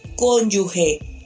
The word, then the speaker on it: cónyuge: Por lo tanto, no puede pronunciarse cónyuge. Es cónyuge.